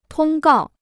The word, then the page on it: 通告: tōng gào